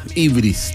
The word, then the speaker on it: Hyvris: H Y-V-R-I-S. – Ah, facilito, no hay problema. Ibris, tal Ibris. Como suena, pero con H.